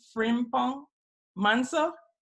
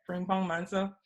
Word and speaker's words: Frimpong-Manso: Director is Shirley, and Tolu, you have to help me from your Ghana experience with the pronunciation of her name, Shirley Frimpong-Manso. – Frimpong, yeah, that's perfect. – Frimpong, okay. Frimpong-Manso.